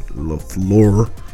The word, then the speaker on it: LaFleur: For sure, Mark McCarthy, who was his last coach, and now Matt LaFleur. Pronounced that right. – There you go.